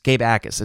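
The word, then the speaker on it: Jacas: Gabe Akis, it's a silent J, really making an impact out there.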